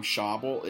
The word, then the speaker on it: Schawbel: It's by a guy named Dan, I think you pronounce his last name Schauble. It's Dan, like Dan rhymes with man, and then S-C-H-A-W-B-E-L.